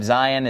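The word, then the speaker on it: Zayin: The pronunciation of Zion is, obviously the letter makes a Z sound, Zion. But the word Zion, it's pronounced pretty similar to the place in the Bible, Zion.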